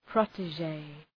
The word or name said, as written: protege